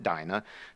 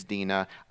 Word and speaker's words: Dinah: I know it's spelled D-I-N-A, and we in the Western world would pronounce it Dinah. The Hebrew pronunciation is Dinah.